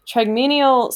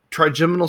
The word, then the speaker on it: trigeminal: And two is a, I'm going to say this wrong, trigeminal stimulant, which I already knew. – Trigeminal stimulant.